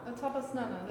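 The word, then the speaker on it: Ātapasnāna: Oh, it's interesting that you bring out the term sunbathing, because that links to the title of the work, isn't it? Atapasnana? – Atapasnana. That's good pronunciation.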